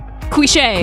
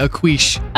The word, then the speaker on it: cliché: Cliché. – Cliché. – Yes. – He pronounced it a quiche.